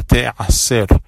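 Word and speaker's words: te'aser (תעשר): En la Torah, cuando se habla de este tema, dice la palabra te'aser.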